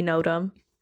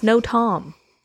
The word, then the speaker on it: NOTAM: Well, you'd think it would be Notam, but it's no Tom.